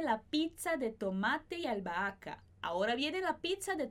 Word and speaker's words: albahaca: Tomate e albahaca, esse H não vai ser pronunciado, mas os dois As que vêm antes e depois, os dois são pronunciados.